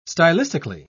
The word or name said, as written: stylistically